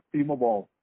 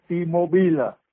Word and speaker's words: T-Mobile: Và nó phủ coi như là đeo cái fula lên có chữ timo bồn gì đó nó đọc là timo bồn nhưng mà mình đọc tiếng Tây là timobile.